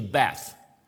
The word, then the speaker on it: Beth: And this means, this pronounce, if I pronounce correctly, should be Beth.